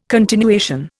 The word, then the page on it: continuation: Indian